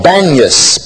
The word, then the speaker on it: Banias: The city today is called Banias because the Arabic-speaking people can't pronounce a P, so they call it Banias instead of Panias.